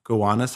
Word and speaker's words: Gowanus: Washington also sends 3,000 men to a wooded, hilly area called Gowanus Heights, which is really funny because it's spelled G-O-W-A-N-U-S, so Gowanus Heights.